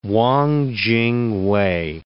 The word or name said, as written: Wang Ching-wei